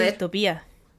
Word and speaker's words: distopía: Se dice distopía. Tiene un tilde en la I. En la última.